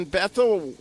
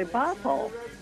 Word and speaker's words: Bethel: We got is in Bethel, Washington, I think. Did I say that right? – It's actually Bethel.